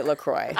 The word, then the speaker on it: LaCroix: How to officially pronounce LaCroix. – LaCroix.